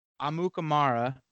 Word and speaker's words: Amukamara: So, Ha Ha Clinton Dix, Eric Reed, Prince Amukamara. I think I pronounced that right. – You did. You got it.